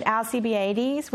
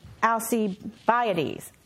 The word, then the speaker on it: Alcibiades: There is I always said Alcibiades, which is completely wrong. This is Alcibiades. That is how you say it, but my kids don't even know that to this day, probably.